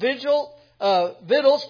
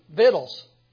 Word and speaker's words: victuals: And those officers provided victuals for King Solomon and that's how you say that, by the way. Don't look at me like that. That's how it's pronounced. And that's how we say it in the South, victuals. It's spelled that way, but it's pronounced victuals.